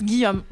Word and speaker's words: Gijom: Alors ça s'écrit G I J O M. – Gijome. – Oui, c'est exactement ce que me dit Léo. Ça se prononce Guillaume en espéranto.